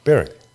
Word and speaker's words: Berwick: How do you say Berwick? Do you call it Berwick or Berwick? – Berwick. – Berwick, all right.